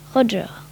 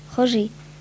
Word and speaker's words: Roger: C'est elle qui a choisi le prénom de son fils et tout naturellement dans la prononciation de sa langue natale, Roger. – Au début, Roger Federer n'apprécie pas tellement que l'on prononce son nom à la française, Roger.